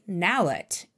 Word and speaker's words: nallet: It was things like, this is how you say nallet. It rhymes with ballot, and a few little name things, and then it's cool, have fun, record.